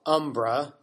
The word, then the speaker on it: Umbra: I think both pronunciations are fine, but I tend to call it Umbra.